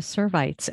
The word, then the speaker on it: Servites: I would love to hear how you wound up deciding to be part of the Servites. And am I saying that right? I should ask that too. – Yes, you are.